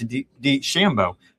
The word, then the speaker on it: DeChambeau: He pointed out that there's been two events this year on LIV where Bryce DeChambeau has talked about the idea.